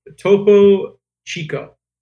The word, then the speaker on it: Topo Chico: All right, the last one I got is Coca-Cola decided to get into the hard seltzer game with Topo Chico. I don't know if I'm pronouncing that right. Johnny, do you want to help me out? – That was great.